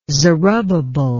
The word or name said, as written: zerubbabel